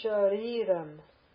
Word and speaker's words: shariram: A-a-a. Karanam. Karanam. Shariram. See the sh? It's a palatal sh because of the little mark you see above the sh, the accent. Sh, long e, shariram. Shariram.